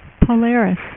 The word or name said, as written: Polaris